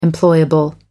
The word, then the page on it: employable: American English